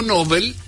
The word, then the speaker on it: Nobel: El sustantivo Nobel y el adjetivo Nobel, según nuestro oyente, son voces agudas. Se tientan en la última sílaba.